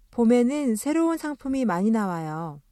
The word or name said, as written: 새롭다